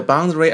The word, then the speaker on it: boundary: The boundary around the round ground separates us from the surroundings. 好，先来看第二个单词 boundary. Boundary, A 不发音，念成 boundary 也可以啊。